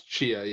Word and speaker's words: Tchia: It's it's Tchia, yeah.